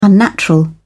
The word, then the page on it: unnatural: British English